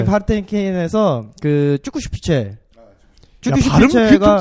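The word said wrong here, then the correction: Zugspitze: 야, 발음 그 좀, 야, 쭉, 뭐, 아씨. 축 슈피체. 축 슈피체.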